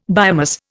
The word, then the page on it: biomass: Indian